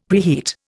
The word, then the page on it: preheat: Indian